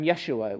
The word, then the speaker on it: Yeshua: Now, Jesus uh, would have been pronounced at that time Yeshua.